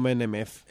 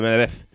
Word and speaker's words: Medvedev: Medvedev, no Menemev. – Vladimir Putin. No, se pronuncia como en ruso, Medvedev.